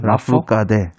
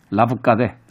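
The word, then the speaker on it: Lafourcade: 라포카데. – 라포카데.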